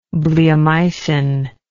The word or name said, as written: bleomycin